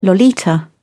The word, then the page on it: lolita: British English